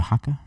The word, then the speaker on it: haka: Am I saying it right though? I think so. – Yeah, haka. I think so. It's the haka. – Yeah, I, th- I believe that's correct.